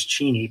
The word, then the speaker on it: Cheney: but rather that the name Cheney, which is pronounced in Wyoming as Cheney, but nevertheless was a political asset in the state of Wyoming.